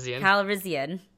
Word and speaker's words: Calrissian: Lando Carlson is back, and Lando Carlissian. – Calrissian, I can't say his name, is back.